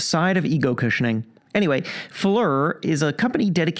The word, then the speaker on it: Phlur: And he talked recently to a man who launched, the company is spelled P-H-L-U-R, which I wasn't sure how to pronounce. So I actually called the company to ask. – It's Fleur, not Floor. – Yes. – Fleur. – Fleur, that's correct.